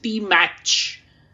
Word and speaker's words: Stimac: And going by his spelling, I think it may be pronounced Stimach. I-G-O-R.